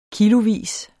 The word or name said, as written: kilovis